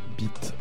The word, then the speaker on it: Byte: Eh bien, il se prononce Byte. B-Y-T-E.